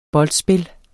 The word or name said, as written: boldspil